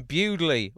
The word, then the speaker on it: Bewdley: I'm thinking your 1st of February gig may well be in the Midlands again, in Bewdley, which I think is the pronunciation on that one.